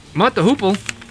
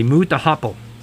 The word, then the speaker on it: Mott the Hoople: Mott the Hoople, and I have to make sure I say it correctly, because I'm always, when Mott the Hoople comes on, I always look at my wife and say, Moot the Hopple. And she's like, no, it's Mott the Hoople.